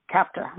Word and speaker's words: CAPTA: In 1974, Congress passed a law called CAPTA, this is how you say the acronym, it's the Child Abuse Prevention and Treatment Act, and they funded it.